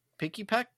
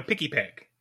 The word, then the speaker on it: Pikipek: And then the last one of the month is Pekipek. No? I said it wrong? Pikipek? – There you go. Pikipek.